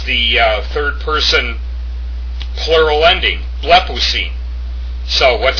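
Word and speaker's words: Blepousine (βλέπουσιν): The next form, blepousine. Blepousine. That's how you pronounce that.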